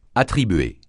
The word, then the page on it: attribuer: francais